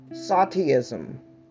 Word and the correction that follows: stoicism: Indifference, stoicism, passivity, sleepiness, alcohol, and drugs.